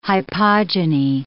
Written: hypogyny

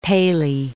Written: Paley

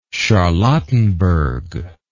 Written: charlottenburg

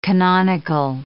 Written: canonical

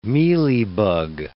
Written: mealybug

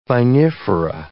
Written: vinifera

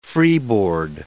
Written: freeboard

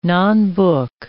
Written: nonbook

